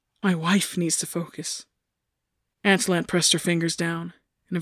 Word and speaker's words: Atalant: Atalant, Emin said with a slight emphasis on the last syllable.